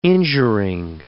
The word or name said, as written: injuring